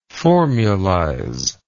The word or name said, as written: formulizer